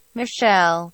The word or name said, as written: Michele